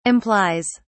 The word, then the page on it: implies: British English